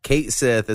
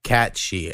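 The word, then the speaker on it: Cait Sith: A random fact I heard recently is Kate Sith is actually pronounced like, it rhymes with Banshee. It's like Catchy, and it's because in like their when you watch their animated versions of this, he has a Scottish accent, Cat Sith. And I guess in Scotland, when it's spelt like that, it's pronounced like she.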